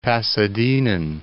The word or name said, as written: Pasadenan